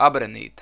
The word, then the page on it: Abrenīte: Abreneete